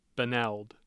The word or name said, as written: benld